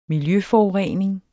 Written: miljøforurening

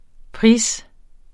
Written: pris

